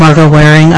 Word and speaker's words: Margot Waring: You did not misspell or mispronounce my name. My name is Margot Waring.